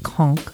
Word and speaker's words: Caen: That's going to be taking place in the capital of Normandy, the city of Caen. That's spelled C-A-E-N.